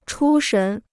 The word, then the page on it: 出神: chū shén